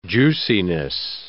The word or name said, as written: juiciness